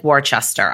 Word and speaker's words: Worcester: Worcester is spelled W-O-R-C-E-S-T-E-R. It looks like Worcester. I didn't spell it wrong, but we pronounce it Worcester if you're from Massachusetts.